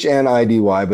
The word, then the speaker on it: Hnidy: I think the one from everything from Heidi, Hindi, Needy, Hinidy. Everybody just so it's Hnidy, but the I and N always get switched.